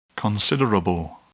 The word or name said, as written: considerable